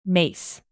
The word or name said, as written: mace